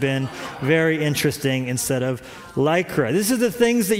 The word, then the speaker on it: Lycra: dirty South and couldn't pronounce Lycra, and so kept saying lacquer, lacquer, lacquer.